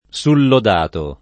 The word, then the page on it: sullodato: lingua italiana